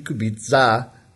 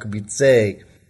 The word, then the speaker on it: Kubica: Since we're going to hear more about Robert Kubica, I just wanted to let you know that you are mispronouncing his last name. It should be Kubica, not Kubice.